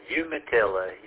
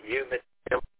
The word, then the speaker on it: Umatilla: It's Umatilla, Umatilla.